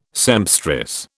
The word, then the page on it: sempstress: British English